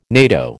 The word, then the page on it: NATO: American English